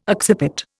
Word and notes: occiput: Indian

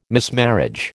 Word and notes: mismarriage: American English

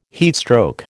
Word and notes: heatstroke: American English

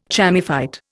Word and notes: chamaephyte: Indian

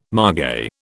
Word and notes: margay: British English

